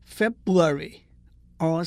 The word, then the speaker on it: February: 二月，F E B R U A R Y，很多人念 February，R 是不发音的。